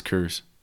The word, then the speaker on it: Coors: It's Coors. – Coors. – Coors. – Coors. It's, everyone here says Coors.